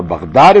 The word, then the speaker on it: Baghdad: or Baghdad as it's more properly pronounced, that's why it's G-H-D-A-D, because it's not a G, Baghdad.